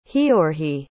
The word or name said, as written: Heorhiy